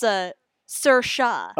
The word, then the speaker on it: Saoirse: It's pronounced Saoirse. – Okay. – It's S-A-O-I-R-S-E.